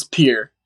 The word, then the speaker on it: Pierre: Is it is it pronounced Pierre or Pierre? It's Pierre. Uh huh.